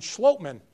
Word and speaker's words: Schlotman: So Austin Schlotman, all those consonants and still a long O for Schlotman.